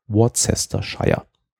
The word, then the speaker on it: Worcestershire: Deswegen heißt es Worcestershire und Leicestershire, aber zum Beispiel Aberdeen Shire.